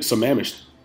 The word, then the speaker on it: Sammamish: Is it Sammamish? How do you say this name? – You did it, Sammamish.